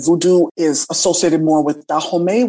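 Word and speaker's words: Vodou: is voodoo. People would typically pronounce the term voodoo. A lot of practitioners of this tradition prefer the pronunciation voodoo to separate it from the way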